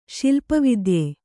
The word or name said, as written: ಶಿಲ್ಪವಿದ್ಯೆ